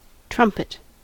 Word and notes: trumpet: American English

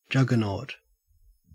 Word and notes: juggernaut: Australian English

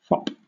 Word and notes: fop: British English

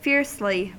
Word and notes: fiercely: American English

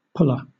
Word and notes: pula: British English

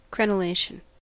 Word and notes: crenellation: American English

